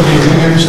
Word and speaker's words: Higgins: Higgins, HRGGINS.